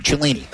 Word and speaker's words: Cellini: You use the promo code Cellini.